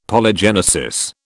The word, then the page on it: Polygenesis: British English